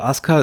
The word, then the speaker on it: Asuka: Also Asuka ist natürlich die richtige Aussprache. Das liegt daran, weil das U im Japanischen oft so ein sogenannter Schwundvokal ist. Das heißt, wenn du vorher einen harten Konsonantenton hattest, dann wird das oft verschluckt. Wenn jetzt zum Beispiel, ja, Rizko ist es ja auch so.